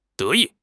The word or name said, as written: de2 yi4